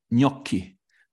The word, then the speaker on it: Gnocchi: Ähm, Gnocchi. Habe ich richtig ausgesprochen? – Gnocchi.